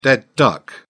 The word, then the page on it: dead duck: British English